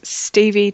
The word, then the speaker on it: Stevie: Yeah, at Stevie Taylor, and that's with two E's at the end of Stevie, just in case you didn't know how to spell that.